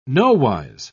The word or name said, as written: nowise